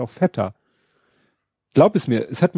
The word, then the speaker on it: Feta: Deshalb meint sie, das heißt Feta das ist ein griechisches Wort und es wird halt so ausgesprochen, wie es, äh, du sprichst ja auch englische Wörter englisch aus.